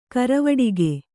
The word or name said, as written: ಘರವಟ್ಟಿಗೆ